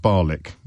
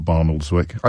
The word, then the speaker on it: Barnoldswick: It's pronounced Barlick. Of course it is, not Barnoldswick.